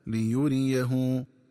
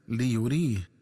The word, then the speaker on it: ليريه: ينبغي التنبه للنطق الصحيح للفظ ليريه، فيقرأ بفتح الياء الثانية وضم الهاء هكذا ليريه، ومن الخطأ قراءته بتسكين الياء وكسر الهاء هكذا ليريه.